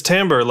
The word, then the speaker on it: timbre: Timber, is it? How do you say that? Timber? Tambor? – I would say timber. Oh, no, you're right. – It's timbre, like the musical term.